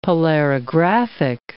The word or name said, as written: polarographic